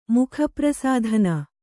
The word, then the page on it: ಮುಖಪ್ರಸಾಧನ: kannada